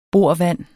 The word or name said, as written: borvand